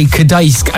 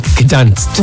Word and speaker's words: Gdańsk: It's actually, well, it says here that it's Gdańsk. So you're supposed to say Gdańsk and not Gdansk.